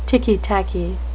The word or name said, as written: ticky-tacky